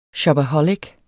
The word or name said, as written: shopaholic